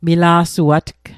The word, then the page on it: mila'suatg: Mi'gmaq